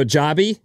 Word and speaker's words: Joby: I don't know if it'll go wide or what, but Joby Aviation. – Joby. – Joby. – How do you spell that? – J-O-B-Y. – Got it. – You want to go Joby? – No, Joby it is.